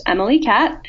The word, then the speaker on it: MLEKAT: It's the letters M-L-E-K-A-T, pronounced Emily Cat.